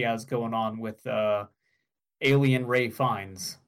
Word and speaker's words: Ralph Fiennes: No, you were right. It's Ray Fiennes. That's how it's pronounced. – Okay. – Yes, for some reason, it looks like Ralph Fiennes, but is pronounced Ray Fiennes.